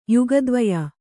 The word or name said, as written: ಯುಗದ್ವಯ